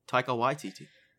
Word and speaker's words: Taika Waititi: I feel like you Taika just butchered his name. – No, his name is Taika Waititi. That's it. I'm not going back on that. That's how you say it.